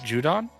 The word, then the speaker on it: Judon: Matt Judon. J-U-D-O-N. – Oh, okay. – What? – That pronunciation was a little sus. So I was like. – Oh, anyway, he's a linebacker. – I just heard Jew. I was like, what? – Judon.